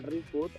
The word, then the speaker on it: ReFood: El proyecto se llama ReFood, o sea, la palabra es inglesa, pero en verdad el proyecto es originado en Portugal. Y lo creó un americano y por eso el término es en inglés, pero sí, es un poco difícil de pronunciar a veces.